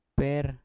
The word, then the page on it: per: mpur